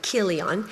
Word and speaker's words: Chilion: It's spelled C-H, but it's pronounced like Chilion.